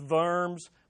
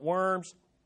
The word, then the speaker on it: Worms: And when summoned by the emperor of the Holy Roman Empire to the city of, in German it's Worms, but I'm not going to pretend I'm German because I'm not, so we'll just call it Worms, for an imperial diet, literally diet, which is just a council.